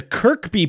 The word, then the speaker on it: Kirkby: The Kirkby part is my surname, and it's pronounced to rhyme with workba.